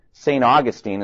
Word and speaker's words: St. Augustine: I think the oldest city in the United States is St. Augustine, is the way we pronounce that.